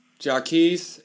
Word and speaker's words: Jacquees: So, I mean, I kind of know Jacquees, is that how you say his name? – Jacquees, and I really like, you know, I'm not going to say, like, you know, who's the guy from Texas?